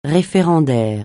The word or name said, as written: référendaire